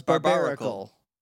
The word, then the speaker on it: Barbaracle: Well, it's barbaracle. Like a barbarian. – Yeah, but a barnacle.